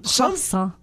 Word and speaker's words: croissant: Is it croissant? – Yeah, croissant.